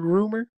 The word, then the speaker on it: RMR: Have you seen this dude, Rumor? It's spelled RMR.